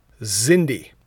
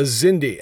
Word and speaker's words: Xindi: And this is called The Zindi. – Okay, The Zindi. – The Zindi. And let me spell it because it's a weird spelling. – X-I-N-D-I.